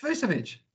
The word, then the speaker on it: Vucevic: V-U-C-E-V-I-C. How could you get Vujicic out of that? – Vucevic. – You're by me. – It's Vucevic.